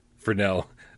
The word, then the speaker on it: Fresnel: As sci-fi game cat Henry says, remember the S is silent in Fresnel, like the S in Gamescast.